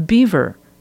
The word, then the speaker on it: Biewer: They named the breed the German Yorkshire Terrier, but their veterinarian suggested calling it after themselves, the Biewer Yorkshire Terrier, pronounced just like the well known rodent, the beaver. It's spelled B I E W E R.